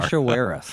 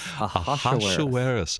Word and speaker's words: Ahasuerus: Ahasuerus. – Ahasuerus.